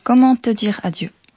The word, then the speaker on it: Comment te dire adieu: Comment te dire adieu.